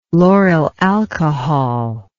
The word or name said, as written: lauryl alcohol